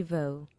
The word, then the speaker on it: Voss: V O double S. It's like mm. two silent S's.